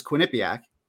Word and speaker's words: Quinnipiac: All right, team to watch is Quinnipiac. Did I say it correctly? – Always, yeah, the hard one to say, but yes! – You got it.